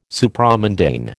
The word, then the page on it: Supramundane: American English